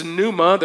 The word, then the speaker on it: pneuma: It's pneuma, there's a silent P, a current of air, blast of breath, a strong breeze, or spirit.